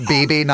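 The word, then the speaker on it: Bebe: That BB Newhart's name is pronounced BB, not Beeb? – Yeah.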